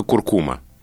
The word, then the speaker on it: куркума: Это слово, которое все живые нормальные люди произносят с ударением куркума. – Да. – Да, а словари настаивают на том, что правильно только куркума.